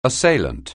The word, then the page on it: assailant: anglais